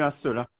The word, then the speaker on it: Hincelin: Non, c'est pas dans le centre-ville, c'est à l'extérieur du centre-ville, à la rue Insula.